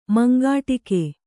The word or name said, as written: ಮಂಗಾಟಿಕೆ